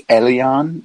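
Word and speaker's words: Elián: I don't think that's how you pronounce his name. – How do you pronounce it? – I think it's Elion. Well, no, you did pronounce it right.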